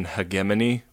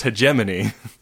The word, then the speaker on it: hegemony: because they like talk about the European hegemony, which is not how I would go about pronouncing that. – No. – It's like 1.21 gigawatts kind of pronunciation. It's like, did you guys not like run this by anybody? Because I'm pretty sure that's pronounced hegemony. So this is a ship that left Earth a long time ago, and they don't have any record of its launch.